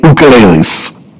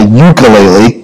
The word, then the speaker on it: ukulele: Now, you have to say it correctly, because if you've been in Hawaii, you can't say a ukulele, because that's the American way of saying it. But in Hawaii, it's an ukulele. That's how you pronounce it.